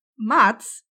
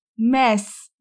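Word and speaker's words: Mads: No i okazało się, że Mats nie jest prawidłową wymową jego imienia. To jest Mes Mikkelsen. Tam nie ma D. Duńczycy nie wymawiają D w tym imieniu.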